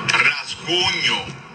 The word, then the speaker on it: Rasguño: Rasguño. palabra echada con fuerza de pronunciación en la penúltima sílaba.